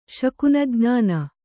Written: ಶಕುನಜ್ಞಾನ

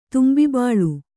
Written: ತುಂಬಿಬಾಳು

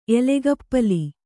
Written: ಎಲೆಗಪ್ಪಲಿ

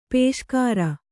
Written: ಪುಷ್ಕರಿ

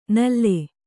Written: ನುಲಿ

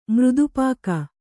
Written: ಮೃದುಪಾಕ

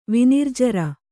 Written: ವಿನಿರ್ಜರ

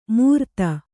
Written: ಮೂರ್ತ

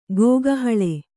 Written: ಗೋಗಹಳೆ

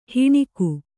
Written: ಹೆಣಿಗೆ

